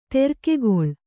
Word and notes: ತೆರ್ಕೆಗೊಳ್: terkegoḷ

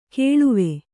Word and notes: ಕೇಳುವೆ: kēḷuve

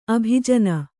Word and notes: ಅಬುಜಿನಿ: Kannada

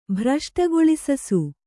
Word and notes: ಭ್ರಷ್ಟಗೊಳಿಸಸು: bhraśṭagoḷisu

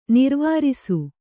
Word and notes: ನೆರವೇರಿಸು: neravērisu

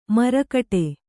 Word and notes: ಮರಗಡೆ: maragaḍe

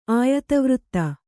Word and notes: ಆಯತವೃತ್ತ: Kannada

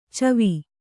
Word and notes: ಛವಿ: chavi

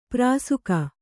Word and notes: ಪ್ರಾಸುಕ: prāsuka

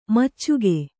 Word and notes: ಮೋಚಿಕೆ: kannada

